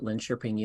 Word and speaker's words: Linköping: And then I did a later PhD at Linköping University. And I'm very impressed, Dr. Danny, that you pronounced that correctly. So you must have some knowledge of Swedish.